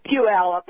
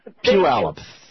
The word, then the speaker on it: Puyallup: How do you say it? – It's Puyallup. – Puyallup. Thank you.